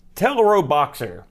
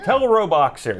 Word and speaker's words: Teleroboxer: I'll go first with my game, and I'm going to try to say this because it's an odd pronunciation. It's Teleroboxer. – Yeah. – Teleroboxer.